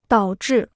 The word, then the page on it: 导致: dǎo zhì